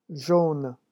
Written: jaune

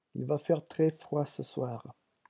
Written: il va faire

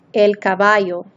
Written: el caballo

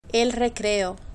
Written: el recreo

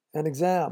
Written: an exam

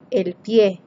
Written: el pie

